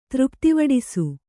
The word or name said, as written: ತೃಪ್ತಿವಡಿಸು